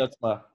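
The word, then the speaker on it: Stützle: What's the German, the Stitzel guy? Am I saying that right? – Stutzla. Yeah, Tim Stutzla.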